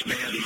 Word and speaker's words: Bowden: Am I pronouncing it right? Is it Bowden or Bowden? – No, it's Bowden, mate.